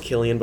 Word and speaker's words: Cillian: I knew that that's just the last 40 minutes, and then I also pronounce Killian, but okay. – It's spelled Cillian.